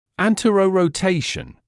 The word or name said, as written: anterorotation